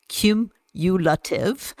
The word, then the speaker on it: cumulative: Cumulative. Is that the right way to pronounce it, Rach? – Yeah. – Cumulative.